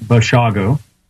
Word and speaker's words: Brashago: It's actually pronounced Brashago, like Chicago.